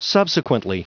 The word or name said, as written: subsequently